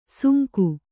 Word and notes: ಸುಂಕು: sunku

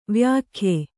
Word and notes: ವ್ಯಾಖ್ಯೆ: vyākye

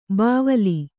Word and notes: ಬಾವಲಿ: bāvali

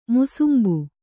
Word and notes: ಮುಸುಂಬಿ: musumbi

